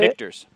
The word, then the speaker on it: Michter's: Speaking of, and it's not bourbon, it's rye, but my wife picked up a bottle of, is it Michter's? Is that how you say Michter's. – It? – Michter's. – Michter's.